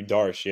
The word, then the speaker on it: Darche: Matthew Darche, yeah. That's what I used. – How do you spell it? – D-A-R-C-H-E.